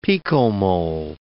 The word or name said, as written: picomole